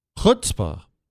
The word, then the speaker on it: chutzpah: How do you say it? You say it for me. Chutzpah. Back of the throat? Chutzpah.